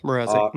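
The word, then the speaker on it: Mrázek: is going to be Peter Mrazek. I'm assuming I'm pronouncing that right. – Yeah, Mrazek. Mm-hmm.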